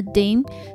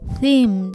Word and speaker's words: theme: 后来我才知道他的 dim 是 theme 的意思，所以他就是发音错误，导致我完全听不懂啊。